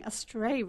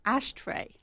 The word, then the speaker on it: Astray: Thank you, and thank you for pronouncing Astray right, because it has shown up on a few press releases as Ashtray.